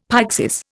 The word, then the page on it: pyxis: Indian